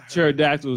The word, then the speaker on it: pterodactyl: Pterodactyl was not started with a T.